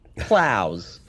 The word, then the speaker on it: Plows: Plows. Rhymes with plows.